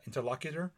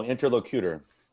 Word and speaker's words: interlocutor: How would you say that? Interlocutor? – No, interlocutor. – Interesting. So my upbringing. – That's how it's pronounced.